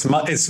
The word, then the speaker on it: Matthijs: And how do I pronounce the Matt? – Is it Matthias? Okay. Matthias? – Yeah, it's Matthijs. – Matthijs.